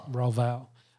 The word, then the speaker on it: Royval: Royval.